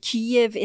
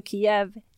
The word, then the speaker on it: Kyiv: fordi at det, eh, å uttale det Kyiv istedenfor Kiev, Kiev eh, er blitt et sånt motstandstegn, da.